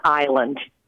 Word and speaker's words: island: I want to know why we don't pronounce the S in island.